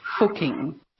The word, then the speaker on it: Fucking: Apparently it's pronounced Fucking. – Fucking? – Because it's Austrian, but that doesn't make it any less funny.